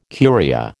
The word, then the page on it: Curia: American English